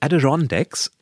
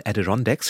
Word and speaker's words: Adirondacks: Also die Adirondacks, wobei ich einen YouTube-Film gesehen habe, wo fünf Menschen es fünfmal unterschiedlich aussprechen. Aber die Mehrheit sagt dann das. Dort nämlich in diesen, ich sag's nochmal, Adirondacks findet seit vielen Jahren ein Zeltlager für Kinder statt.